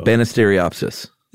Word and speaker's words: Banisteriopsis: Yeah, but it's pronounced like it looks, though. – Yeah, Banisteriopsis.